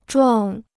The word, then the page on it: 撞: zhuàng